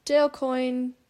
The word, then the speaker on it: Dale Coyne: Dale Coyne, I think I'm pronouncing that right.